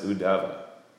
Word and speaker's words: Uddhava: But that's why the devotees say it. Uddhava. And then I think it's pronounced Uddhava.